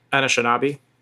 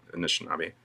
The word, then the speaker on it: Anishinaabe: Anishinaabe. – Anishinaabe.